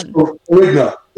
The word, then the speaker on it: Ferrigno: In addition, he is producing a t- TV series called The Reset Plan with the fitness icon and actor Lou Ferrigno. Did I say that right? Um, it's Ferrigno. – Frigno, thank you.